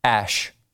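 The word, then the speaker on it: Æ: The other thing, he explained that the A-E, how would you pronounce that? Think in your head right now, how would I pronounce A-E? Yes, Ash.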